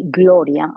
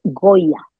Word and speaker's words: Gloria: I couldn't say Gloria. I say Goya.